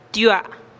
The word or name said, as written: tiuaa